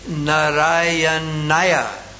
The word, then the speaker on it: Narayanaya: It's actually not Om Namo Narayana, but Om Namo Narayanaya. So the last syllable is the Ya, Narayanaya.